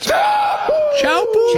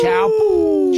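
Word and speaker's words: Teahupo'o: I believe it's pronounced Chaupu. – Chaupu.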